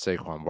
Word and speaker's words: Saquon: Saquon Barkley. – Good job. – Saquon. – No, you said it right. You said it right the first time.